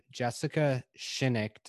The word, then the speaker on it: Jessica Shinnick: And with us, we have Jessica Shinnick.